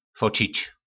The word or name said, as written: focic